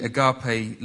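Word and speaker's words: agape (ἀγάπη): And the original title that I was given was Agape. That's the way I was always taught to say it. It's actually agape is the official pronunciation.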